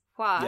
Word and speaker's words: Hua: Um, even with Mulan's last name, because like it's it oh, I'm sorry, my pronunciation is bad. It's Hua yeah.